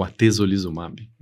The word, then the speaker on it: atezolizumab: Tem uma aqui que é a dostarilimab e a atezolimab. Como que é a estratégia de tratamento? Eu falei certo isso? Sim. O atezolizumab, né?